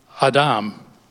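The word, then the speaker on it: Adam (אָדָם): Now, that Hebrew word is, according to the Hebrew scholars, it's pronounced Adam. The accent's on Dom. Now, that word we say in English is Adam, okay? But in Hebrew, it's Adam.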